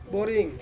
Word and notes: boring: Ansus

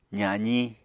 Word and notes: zaziñ: apatani

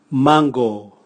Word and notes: mango: Raga

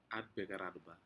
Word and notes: anpeke aranma: Aneityum

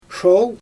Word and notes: ʃol: Xyzyl

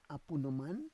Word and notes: apu noman: Roon

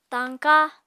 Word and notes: tañka: apatani

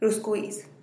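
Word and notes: ruskwiiz: Teotitlán del Valle Zapotec

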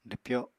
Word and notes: dwpyo: apatani

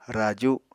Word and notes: raju: Apatani

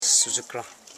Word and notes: sudgukran: su.dgu.kran; Koro

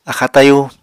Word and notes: axáa tayú: apatani

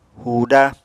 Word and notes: huuda: apatani

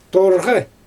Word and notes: torɣɯ: Xyzyl

